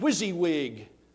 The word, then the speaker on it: WYSIWYG: And it spelled this on the screen, W-S-I-W-Y-G. What you see is what you get. And they pronounced it WYSIWYG.